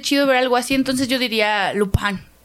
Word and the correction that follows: Lupin: No sé si se pronuncia así, hermano. Lupin. Así se Lupin, pronuncia.